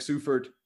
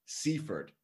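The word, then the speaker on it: Seufert: Eric Kress, myself, Misha Katkov, Eric Sufert, and Adam Telfer. And today, just like a couple of minutes ago, we finally decided that's Eric Sufert, not Seifert, right, Chris?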